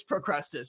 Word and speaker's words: Procrustes: evidently it's Procrustes.